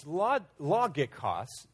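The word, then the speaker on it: logikos (λογικός): It's logikos. You know, the Greek pronunciation, we use a hard G sound, but we see it as logikos, which, of course, we get our word logic from, right?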